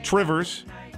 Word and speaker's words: Two Rivers: Manitowoc Crane coming after their employees, and now in Two Rivers, or as the locals say, Trivers.